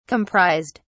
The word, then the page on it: comprised: British English